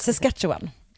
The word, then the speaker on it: Saskatchewan: Saskatchewan. Jag tror att det är så man mm. uttalar det.